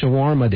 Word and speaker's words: shawarma: And the last one is National Shawarma Day, if I said that right. S-H-A-W-A-R-M-A. Shawarma? Shawarma?